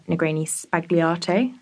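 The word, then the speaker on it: Negroni Sbagliato: So instead, I've gone for a Negroni Spagliato. – Okay. – I have no idea if I'm saying that correctly. – Sounds right. Just say it quickly.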